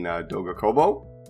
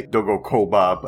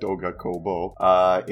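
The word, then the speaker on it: Doga Kobo: It's done by pretty much my favorite studio, being Dogokobo. I always mispronounce that name, but it's Dogokobo. I always want to say Dogokoba, but it's the other way around, Dogokobo.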